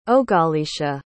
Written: ogaleesha

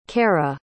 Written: kara

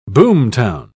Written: boomtown

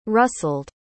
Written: rustled

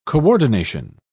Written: coordination

